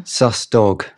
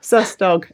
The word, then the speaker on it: Sus Dog: Susdog. – Susdog, excellent, yes.